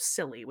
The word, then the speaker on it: Scilly: So this ship ends up wrecking in an uninhabited island called the Isle of Scilly, which is hilarious, by the way. It's Scilly spelled like S-C-I-L-L-Y because it's like outside of Cornwall area. But Scilly. So it crashes and wrecks in a storm on December 14th, 1907.